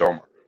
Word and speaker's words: Umar: It's spelled Umar, but it's pronounced Omar.